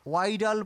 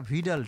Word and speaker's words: Widal: এনারা একটা পরীক্ষা করেন, যে পরীক্ষাটাকে বিডাল পরীক্ষা বলে, অনেকে উচ্চারণ ভুল করে এটাকে ওয়াইডাল পরীক্ষাও বলে, WIDAL, কিন্তু উচ্চারণটা ভিডাল টেস্ট।